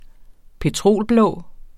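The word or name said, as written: petrolblå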